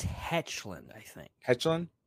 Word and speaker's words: Hoechlin: How do you pronounce it? – I think it's Hoechlin.